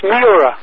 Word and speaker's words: Hmura: It's the name is H-M-U-R-A. The H is silent. – Right. I'm sorry, because I pronounced it Hamura. – Yeah, it's Mura.